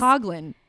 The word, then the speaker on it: Coughlin: And then by the end, she's like, it sounds like they're saying goblin. – Yeah. And I was like, yeah, what? His name is Doug Coughlin, but he has this Coughlin. That's why I was saying it wrong.